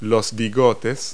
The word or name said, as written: whiskers